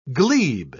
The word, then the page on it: glebe: British English